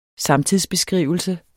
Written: samtidsbeskrivelse

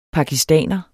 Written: pakistaner

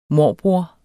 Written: morbror

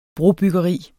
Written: brobyggeri

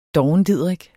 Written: dovendidrik

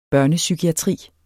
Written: børnepsykiatri

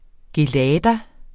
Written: gelada

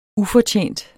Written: ufortjent

